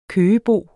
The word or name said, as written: køgebo